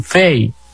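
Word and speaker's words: Fay: Ella se llama tormenta tropical Fai. Los americanos dicen Fei, pero se escribe Fai.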